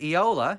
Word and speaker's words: Eola: Lake Eola. Have I said that right? – Yeah. Yeah. I love how you're asking me.